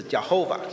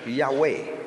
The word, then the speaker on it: Yahweh: 耶和华其实真正的念法不是 Jehovah，现在我们英文叫 Jehovah，其实原来的犹太文可能应该是念成 Yahweh， Yahweh。所以他称 Yahweh 的时候就是指亲近的神，称 Elohim 的时候是指伟大的神。那这边是整个创世纪独有的一段，他是用 Yahweh Elohim，就是耶和华神，两个名字都放在一起。